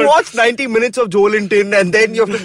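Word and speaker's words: Joelinton: You know, it's apparently pronounced how Wellington is, Joe Linton.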